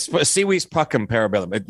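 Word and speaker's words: Si vis pacem para bellum: It's Civis Pacem Parabellum. The V is pronounced the W, but it's, yeah, I'm sorry.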